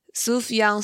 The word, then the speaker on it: Sufjan: Sufjan Stevens 哦，我还特别去查了一下他的英文名字要怎么念，这样子，因为他拼起来很神秘，是 S U F J A N，然后 Stevens。